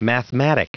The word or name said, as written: mathematic